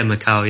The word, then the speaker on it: Macau: Or am I pronouncing that right? – Yeah, Macau. Yeah.